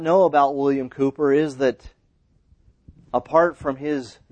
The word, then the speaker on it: Cowper: In the hymn books, you'll see that his name, the English spelling of that is C-O-W-P-E-R, but it's pronounced Cooper.